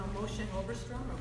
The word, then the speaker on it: Moshe Oberstrom: You know, like all these Moshe Oberstrom, I'm saying his name right? – Right.